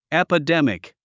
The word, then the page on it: epidemic: èpədémik; エパデミク